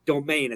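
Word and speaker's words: demesne: Unfortunately, this is just a demense, or domain as it's pronounced, spelled such as this, I am typing it in the chat.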